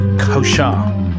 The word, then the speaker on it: Koosje: Her name is Koshar. She self-released her debut single in 2019, and that is a lovely tune from 2024 called Quiet Portrait. K-O-O-S-J-E, pronounced Koshar.